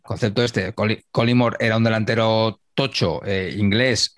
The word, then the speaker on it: Collymore: Pero me hacía mucha gracia cómo le llamaba García, cómo pronunciaba su apellido García, que era Colin Morey. – Stan Collymore la ha vuelto a liar.